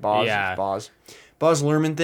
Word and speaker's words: Luhrmann: Baz Luhrmann thing where he likes to cut really fast and do a lot of stuff.